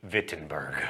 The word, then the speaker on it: Wittenberg: Also, let me say retroactively, now that everyone has commented on my poor German pronunciation, Wittenberg.